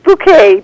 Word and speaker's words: Bucket: It's Bouquet, dear. Yes, yeah. – That's right. – Yeah, that's right. Bucket. It's spelled Bucket.